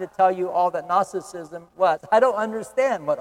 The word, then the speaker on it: Gnosticism: And that's spelled G-N-O-S-T-I-C-I-S-M, Gnosticism.